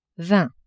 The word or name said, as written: vingt